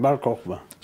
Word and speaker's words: Bar Kokhba: And then again in 135, the Bar Kokhba, how do you say that? – Bar Kokhba.